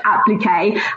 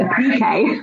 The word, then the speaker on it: appliqué: So, and I think a French would say appliqué. And I think for some reason the English, we emphasize a different syllable. So we always say appliqué.